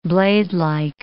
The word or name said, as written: bladelike